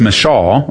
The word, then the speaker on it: Michal: So sad, because Saul, one of the things you see here with Merab, as well as Michal, or Michael, some people have a varying pronunciation of her name.